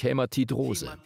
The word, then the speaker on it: Hämatidrose: Dieser Zustand nennt sich Hämatidrose. Okay, ich habe es richtig gesagt. Er nickt. Preis den Herrn. Danke, Jesus. Ich dachte schon, ich müsste eine Weile in Zungen beten. Hämatidrose, das ist, wenn der Körper unter extremem physischen oder emotionalen Stress steht.